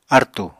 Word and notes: artù: Apatani

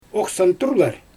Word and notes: oxsantyrlar: Xyzyl

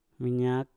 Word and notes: minyak: Mpur

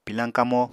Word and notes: pilañ kamo: Apatani

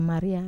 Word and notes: maria: Wamesa

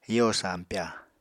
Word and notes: yo sampya: Apatani

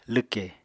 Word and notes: lwkè: apatani

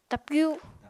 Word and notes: tapyu: Apatani

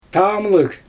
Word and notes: tamlɯɣ: Xyzyl

